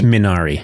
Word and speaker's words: Minari: I'm going to make sure I'm saying it right. Minari. – Minari. I no judgment if anybody says it's Minari, but like how it's like asking.